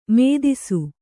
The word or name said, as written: ಮದಿಸು